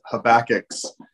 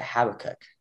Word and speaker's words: Habakkuk: It relates to I've always pronounced it Habakkuk. – Okay, that's fine. – Is it Habakkuk? Habakkuk. – Habakkuk. – Either way. – Habakkuk. Either way, it doesn't matter.